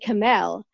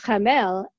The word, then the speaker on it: Khamel: I said Kamel. Uh-huh. But apparently it's pronounced Khamel, and it's Arabic, and it means inert.